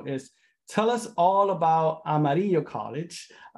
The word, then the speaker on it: Amarillo: That's how I pronounce it, by the way, Amarillo College. How do you pronounce it? – We say Amarillo.